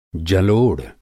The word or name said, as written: जलोढ़